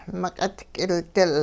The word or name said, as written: miq'it k'ildil